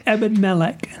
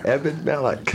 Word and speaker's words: Ebed-Melech: Ebed-Melech. – Ebed-Melech.